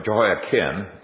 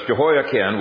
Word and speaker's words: Jehoiachin: The second thing that he planted was the ancestor of Jesus, Jehoiachin, or Jehoiakim, it's pronounced, not Chin, it's spelled Chin, I'm not sure why. But it's Jehoiakim,